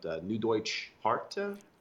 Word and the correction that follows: Neue Deutsche Härte: Neue Deutsche Härte. Yeah, I know what. NDH. Just say NDH. Neue Deutsche Härte. This is New German Hardness.